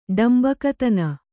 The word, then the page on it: ಡಾಂಭಿಕತನ: ḍāmbhikatana